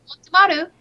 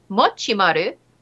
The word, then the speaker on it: Mochimaru: A dignified Motimaru, whose name is actually pronounced Mochimaru, showing reserved joy while posing with his various YouTube awards.